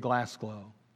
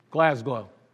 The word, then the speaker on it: Glasgow: Years ago, a man was walking in the east end of the city of Glasgow. Said it wrong. It's only one S. Glasgow.